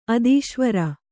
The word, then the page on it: ಅಧೀಶ್ವರ: adhīśvara